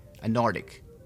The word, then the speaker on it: Antarctic: Pero cuando lo dice rápido, cuando dice a Nordic, parece que la C en medio no se escucha. Pero si lo dice más despacio, es cuando se va, lo debe de pronunciar.